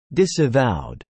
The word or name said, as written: disavowed